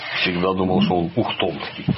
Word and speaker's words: Ухтомский: И сегодня я хочу рассказать, с одной стороны, известный, с другой стороны, в широкой публике фактически неизвестный, Алексей Алексеевич Ухтомский. Сразу говорю, что ударение на первую букву, это он.